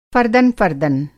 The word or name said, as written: फ़र्दन-फ़र्दन